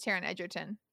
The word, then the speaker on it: Taron Egerton: I can tell you it's Taron Egerton.